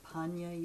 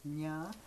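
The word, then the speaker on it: paññā: Panya, you said wisdom, yet it's spelled P-A-N-N-A with the tildes on mm-hmm. top. So do you automatically, if you see an N with a tilde on top, it goes nya? – Yes.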